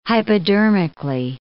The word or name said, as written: hypodermically